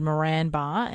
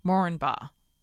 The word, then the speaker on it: Moranbah: I said Moranbah, and he said the accepted way to pronounce the name is Moranbah. The first A is not stressed.